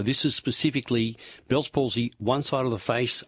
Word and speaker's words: Bell's palsy: What I'd like to know is if having Bell's palsy, I think that's how it's pronounced, yes. – Bell's palsy.